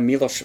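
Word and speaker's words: Miloš: And Amadeus, directed by Milos Forman. I can pronounce that properly. Good, I'm getting a nod of approval.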